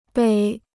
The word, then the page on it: 北: běi